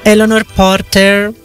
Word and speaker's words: Eleanor Porter: Ed è basata sui romanzi Pollyanna e Pollyanna cresce di Eleanor Porter.